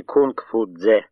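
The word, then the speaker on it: Кунг-Фу-Дзе (孔夫子): «Обратите внимание, они называют имя Конфуция в латинском произношении, а не Кунг-Фу-Дзе, как он произносится по-китайски.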